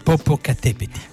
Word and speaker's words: Popocatépetl: Comunque, il guerriero che fuma si chiama, ovvero il Popocatépetl. – Perfetta pronuncia zapoteca.